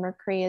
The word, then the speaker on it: Mercree: My middle name, Lee, is spelled L-E-I-G-H. And then Mercree is M-E-R-C-R-E-E.